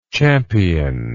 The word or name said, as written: championlike